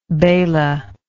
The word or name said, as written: bala